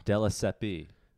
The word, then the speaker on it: Della Siepe: Della Siepe, or is it just Della Sep? – Della Siepe.